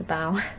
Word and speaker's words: bow: Is it the bow of the ship? It's the bow. I said it wrong.